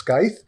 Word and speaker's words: Skyth: Yeah, so basically, this is Super Mario 64 Generations, a mod for Sonic Generations by, I believe it's pronounced Skyth, S-K-Y-T-H.